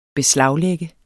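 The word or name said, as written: beslaglægge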